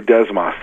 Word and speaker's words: Desmas: I don't know if I think I pronounced that correctly. Yeah, Desmas Records. – Exactly, Desmas.